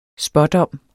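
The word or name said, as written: SPÅDOMMENES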